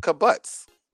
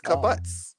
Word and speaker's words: kibbutz: It said the journalists were let into the Kfar Azar kibbutz located just outside the kibbutz. It's kibbutz. Oh. – How you spell that? – Kibbutz. K-I-B-B-U-T-Z.